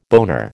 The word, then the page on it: boner: American English